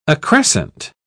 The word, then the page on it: accrescent: British English